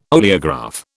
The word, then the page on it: oleograph: British English